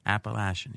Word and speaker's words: Appalachian: UL, Lafayette, Appalachian State. Is that how you say it? Appalachian, yeah.